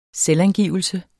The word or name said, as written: selvangivelse